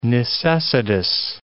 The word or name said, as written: necessitous